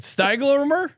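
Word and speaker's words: Stiegelmeier: Stieglmer? – Yes.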